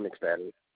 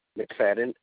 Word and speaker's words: McFadden: It's Antoine McFadden. – Can you spell your first name, please? – A-N-T-W-O-N. – And your last name? – McFadden, M-C-F-A-D-D-E-N. – Can you spell that again for me, please? – M-C-F-A-D-D-E-N.